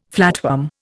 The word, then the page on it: flatworm: Indian